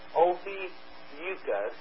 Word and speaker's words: Ophiuchus: Ophiuchus, pronounced Ophiuchus, represents a man with a snake coiled around his waist.